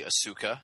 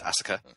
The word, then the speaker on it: Asuka: You know, the Americanization of it would probably be Asuka, but they are going with the Japanese pronunciation, which is basically saying that very fast, Asuka.